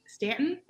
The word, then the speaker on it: Stanton: This is a fun interview that we're doing with Ann Stanton.